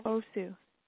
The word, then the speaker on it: Bosu: Bosu? – Bosu. – How do you spell that? – B-O-S-U. – Oh, B-O-S-U, yeah.